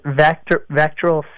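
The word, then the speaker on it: VACTERL: Is it Vater syndrome, or how do you pronounce that? – Yeah, so it's Vectoral syndrome, and it's a birth defect that affects your GI system, and other birth anomalies come with it as well.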